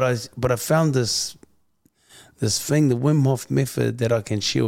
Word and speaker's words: Wim Hof: Like, you're fitness trainer, fight trainer, event promoter, corporate group training, public speaking, and I don't know if I'm saying it right, Wim Hof method. – Yeah, the Wim Hof method.